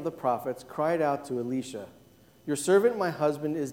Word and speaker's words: Elisha: And as I was reading this, doing my preparation, I looked at the Hebrew on this a prophet's name, and I realized I've been saying it wrong all this time. And maybe you have been too. We've been saying Elisha. It's actually Elisha, looking at the Hebrew.